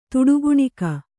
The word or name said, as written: ತುಡುಗುಣಿಕ